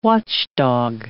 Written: watchdog

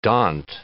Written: daunt

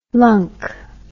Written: lunk

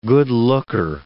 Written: good-looker

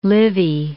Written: Livy

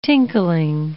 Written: tinkling

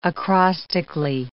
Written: acrostically

